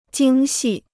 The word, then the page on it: 精细: jīng xì